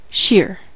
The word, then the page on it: shear: American English